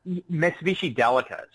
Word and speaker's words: Mitsubishi Delica: Mitsubishi Delica.